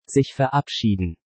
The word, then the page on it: sich verabschieden: German